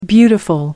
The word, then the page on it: beautiful: American English